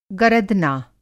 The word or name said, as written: गरदना